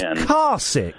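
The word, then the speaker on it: Karthik: K-A-R-T-H-I-K-N-A-G-E-S-N. – And it's pronounced Karsik. – It's pronounced Karsik, yeah.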